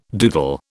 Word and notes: doodle: British English

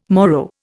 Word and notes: Moro: Indian

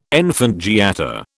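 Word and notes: enfant gâté: British English

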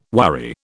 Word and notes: worry: British English

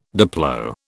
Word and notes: diplo: British English

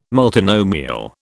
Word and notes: multinomial: British English

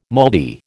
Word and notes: moldy: American English

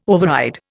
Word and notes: override: Indian

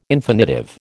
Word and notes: infinitive: American English

